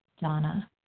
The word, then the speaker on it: Dana: This is Donna B., a recovered compulsive overeater and bulimic, calling from Denver. And my name is spelled D-A-N-A, but pronounced Donna.